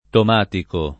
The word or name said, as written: Tomatico